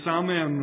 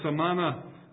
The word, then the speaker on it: Samhain: And it's spelt S-A-M-H-A-I-N. You would naturally say Samhain, or it can be pronounced that way, or Samana, but most people would pronounce it Samhain.